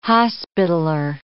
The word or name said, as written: Hospitaler